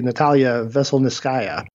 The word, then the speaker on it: Veselnitskaya: Russian lawyer, the lovely Natalia Veselnitskaya. Did I say that right? – Yeah, that sounds good.